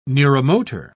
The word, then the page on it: neuromotor: British English